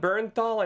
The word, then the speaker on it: Bernthal: If John Bernthal, I never know how to pronounce his name, Mark.